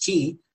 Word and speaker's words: Xi: President Xi, you know, Xi of China, it's pronounced Xi.